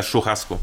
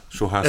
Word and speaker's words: Churrasco: Stichwort Churrasco, spricht man so ja, aus? Ja, Churrasco. Ja, Churrasco.